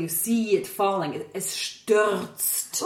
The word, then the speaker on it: stürzt: We really have to phonetically remember that the Z is a TS, and then there's a T at the end, so you get stürzt.